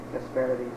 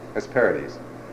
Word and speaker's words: Hesperides: But for our purposes now, she also gave birth, night gave birth to Hesperides. Or who, how do you pronounce it? – Hesperides. – Hesperides.